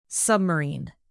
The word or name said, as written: submarine